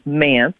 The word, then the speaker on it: MANTS: So I was attending the Mid-Atlantic Nursery Trade Show, better known as MANTS, which rhymes with pants.